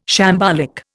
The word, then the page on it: shambolic: Indian